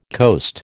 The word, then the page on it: coast: American English